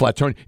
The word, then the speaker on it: Flatonia: It says the folks down there really pronounce the town Flatonia, not Flatonia. – Okay. – It's Flatonia. It's like Lavernia outside San Antonio. You just kind of drag it over with a Y sound, Flatonia.